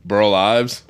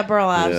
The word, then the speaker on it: Burl Ives: Burl Ives. – Oh yeah, Burl Ives.